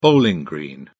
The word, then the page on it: bowling green: British English